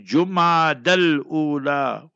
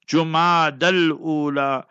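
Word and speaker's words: Jumad al-Ula (جمادى الأولى): Pronounce it correctly. Jumad al-Ula. With the Dhamma Pesh on the Jeem. Jumad al-Ula.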